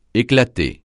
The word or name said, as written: éclater